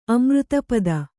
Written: ಅಮೃತಪದ

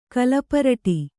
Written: ಕಲಪರಟೆ

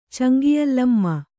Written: ಚಂಗಿಯಲ್ಲಮ್ಮ

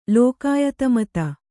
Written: ಲೋಕಾಯತಮತ

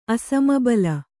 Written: ಅಸಮಬಲ

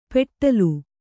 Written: ಪೆಟ್ಟಲು

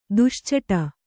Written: ದುಶ್ಚಟ

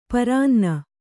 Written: ಪರಾನ್ನ

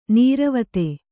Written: ನಿರ್ವಾತ

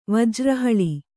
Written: ವಜ್ರಹಳಿ

